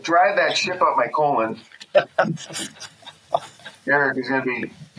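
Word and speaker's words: Colón: No, it's Colon. Definitely Colon. – You had it right the first time.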